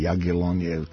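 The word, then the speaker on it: Jagiellonia: Uspešan je bio rezultatski i Radnički iz Niša, koji je bio bolji od Jagiellonije ili Jagiellonije, kako već oni izgovaraju, i sa Lade ih sa 2-1.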